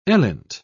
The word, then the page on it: elint: British English